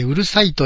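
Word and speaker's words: 五月蝿い: 文字でさえ、 5 月のハエと書いてうるさいと読ませます。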